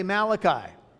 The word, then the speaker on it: Malachi: That's the proper way to say Malachi.